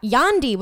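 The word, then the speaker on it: Yandhi: Yandi, which is like supposed to be like, so Gandhi, but spelled with a Y instead of a G.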